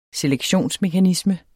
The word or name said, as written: selektionsmekanisme